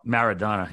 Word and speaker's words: Maradona: How do you say it? Maradona? – Maradona. Yeah, Diego Maradona.